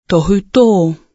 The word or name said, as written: tohuto